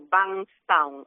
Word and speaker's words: Bankstown: Sí, pero al final se viene pronunciando como Bankstown, algo así lo pronuncian ellos y así lo intentaremos pronunciar nosotros, aunque nos costó trabajo cuando estuvimos allí pronunciarlo correctamente.